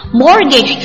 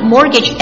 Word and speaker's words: mortgage: Mortgage 注意一下，mortgage m o r t g a g e， mortgage t 是不发音的，表示什么？